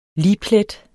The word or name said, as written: ligplet